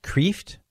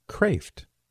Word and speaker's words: Kreeft: And I know his name looks like it would be pronounced Kraft. That's how I would pronounce it. But I know Peter, and it's pronounced Kraft. K-R-E-E-F-T.